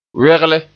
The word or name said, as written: wegle